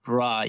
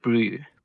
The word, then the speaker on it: bru: Yeah, it's bruh, B-R-U, bruh. That's what guys call each other now. – B-R-U. So we don't pronounce it bra, yeah. We pronounce it brew.